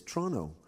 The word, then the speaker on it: Toronto: You say Toronto. The T's completely disappeared except for the first one. I have no idea. And even the newsreaders, it's Toronto. So I think you should respell it T-R-O-N-O.